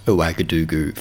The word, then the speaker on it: Ouagadougou: I'm also really relieved that I pronounced Ouagadougou first take.